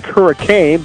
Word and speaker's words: Hurricane: It's spelled like hurricane, but it's pronounced hurricane, at Copper Rock, the golf course there.